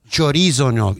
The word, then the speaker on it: chorizo: Chorizo gnocchi. – You said it right.